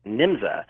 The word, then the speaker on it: NYMZA: And there was an organization in Germany called NYMZA, N-Y-M-Z-A. And some spellings have it N-J-M-Z-A, but it was still pronounced NYMZA.